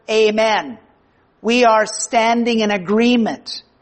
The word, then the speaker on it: amen: It's pronounced here in the great Greek text as amen. And in English, we pronounce it as amen or amen, depending on what circles you grew up in church life.